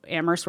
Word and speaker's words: Amherst: And as Paul said earlier, Amherst, we're only the H is silent.